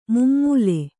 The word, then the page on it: ಮುಮ್ಮೂಲೆ: mummūle